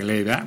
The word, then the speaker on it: Glade: si eh, sí, sí se pronuncia Glade, Bueno, me da igual.